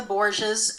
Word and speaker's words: Borgias: Borgias.